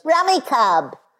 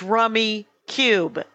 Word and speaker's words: Rummikub: However, the computer on my phone, when it wins, goes, Rummy Cub. – I've researched it. When everyone knows that it is Rummy Cube. – Yes, go to the Rummy Cube website. It'll say pronounced Rummy Cube.